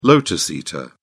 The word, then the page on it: lotus eater: British English